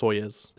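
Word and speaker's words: Soyuz (Союз): Stop pronouncing it wrong. It's Soyuz.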